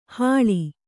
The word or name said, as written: ಹಳಿ